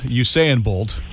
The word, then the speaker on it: Usain: Usain Bolt.